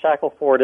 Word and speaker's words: Shackleford: Shackelford. Yes. – No relation to the famous explorer, is there? – No. He's Shackleton. Shackelford is an old English name.